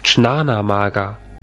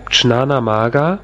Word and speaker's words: Jnanamarga: Dann gibt es einen, der ist ein bisschen schwierig auszusprechen, der heißt Jnanamarga. Da spricht man also so, wie ich es da hinten geschrieben habe, Jnanamarga.